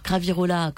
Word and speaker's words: Cravirola: C'est, alors Cravirola, comme ça se prononce en fait, C-R-A-V-I-R-O-L-A.